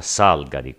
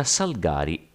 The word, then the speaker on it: Salgari: Emilio Carlo Giuseppe Maria Salgari al riguardo bisogna sottolineare che, anche se la pronuncia Salgari con l'accento sulla prima sillaba è indubbiamente diffusa, la pronuncia Salgari è però quella corretta, dato che si tratta di un cognome fitonimico che deriva dal nome di una pianta, il Salgar appunto.